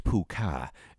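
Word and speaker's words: púca: The meaning of puka, pronounced puka, is from the old Irish word puka, which means goblin. And there are many variations of spelling a puka, including P-U-F-O-D-A, P-I-L, P-H-U, Fuka, you fucker.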